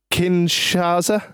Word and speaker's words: Kinshasa: Kinshasa.